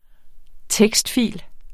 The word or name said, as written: tekstfil